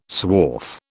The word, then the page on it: swarf: British English